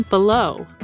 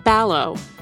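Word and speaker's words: Balogh: He refers to one of the historians interviewed as Brian Below, but the University of Virginia professor's name is actually pronounced Ballow.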